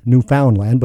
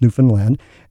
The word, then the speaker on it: Newfoundland: And for you more English-speaking Canadians, Newfoundland, but it's actually pronounced Newfoundland.